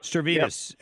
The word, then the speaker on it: Stavridis: Did I say his name right? – Stavridis.